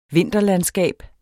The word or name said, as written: vinterlandskab